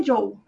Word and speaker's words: Zhou: Mas eu acho que é Zhou. fala tipo como se fosse um D, um DJ.